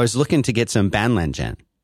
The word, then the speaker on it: Banlangen: That is how I pronounce the Chinese. It is spelled Banlangen.